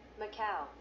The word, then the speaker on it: Macau: Macau.